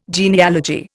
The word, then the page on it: genealogy: Indian